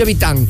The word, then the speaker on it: Vitane: Se escribe Vitane. Vitane. Se pronuncia Vitán.